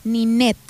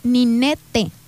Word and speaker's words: Ninet: Pónganle, mi nombre se escribe como Ninel, pero con T, Ninet. Entonces es Ninete. Ninete.